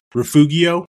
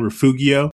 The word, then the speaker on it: Refugio: I got in an argument with my professor because he was talking about refugio because that's how it's spelled. You know, when you go through Goliad and you keep going south, and he kept saying Refugio, and I raised my hand. I said, sir, you know, it's Refugio.